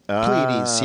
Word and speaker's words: Pleiades: This song is called Pleiades. Am I pronouncing that correctly? – Pleiades. – Pleiades, yeah.